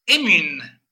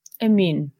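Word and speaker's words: Emyn: Emin, ok. – Y ya escrito con Y. – Uh-huh. – Emin. Tolkien dice que si lo pronuncias con la I normal, que no pasa nada.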